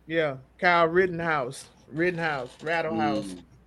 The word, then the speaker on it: Rittenhouse: You're talking about Rattle House, Rittenhouse. – Yeah. Rittenhouse.